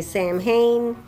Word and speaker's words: Samhain: It's a joke, right, because it's spelled S-A-M-H-A-I-N, and a lot of people say Samhain, but it's pronounced Samhain.